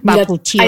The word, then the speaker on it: bakuchiol: bakuchiol. ¿Lo he dicho mal o lo he dicho bien? – Bakuchiol. Ahí tenemos una montada, dice la doctora María Jesús Lucero, que es bakuchiol.